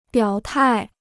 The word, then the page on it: 表态: biǎo tài